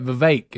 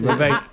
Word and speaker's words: Vivek: It's actually, that's not how you say it. Vivek is how you say it. Vivek. Yeah.